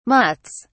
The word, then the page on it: mutts: British English